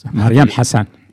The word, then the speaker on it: Mariam Hassan: Pues muy bien, vamos a escuchar a esta mujer, a Mariam Hassan, que no lo digo bien, lo sé. – No, está bien. Mariam Hassan.